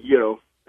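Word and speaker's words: gyro: Yeah, so you do pronounce it Euro. That's right.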